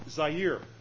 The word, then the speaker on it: Zaire: You say it, and it comes out Zaire, and that's how you pronounce it.